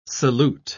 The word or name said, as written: salute